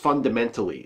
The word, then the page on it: fundamentally: American English